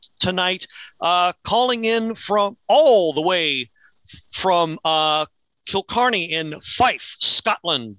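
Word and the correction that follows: Kirkcaldy: Did I say that right, Mr. Green? – Kilkaldy.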